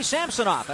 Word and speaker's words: Samsonov: And Sergei Samsonov, that's how we've decided to say his name for this playoff series, has always faced the Sharks and played against them very well.